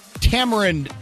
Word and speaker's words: tamarind: I don't know if I know what tamarind is, and the reason I'm pronouncing it that way is because I went straight to the Google and heard that you pronounce it like this.